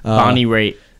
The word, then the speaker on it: Bonnie Raitt: Bonnie Raitt.